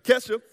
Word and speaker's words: Kesha: Oh, I was saying Keisha earlier. Anyway, Kesha, she says it like this love is like a drug, right?